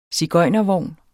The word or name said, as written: sigøjnervogn